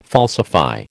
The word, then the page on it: falsify: American English